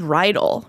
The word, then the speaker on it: Riedel: There's this blog that was like, it's spelled Riedel, like Riedel High School in the John Hughes movie. Okay, but they were like pronounced like needle.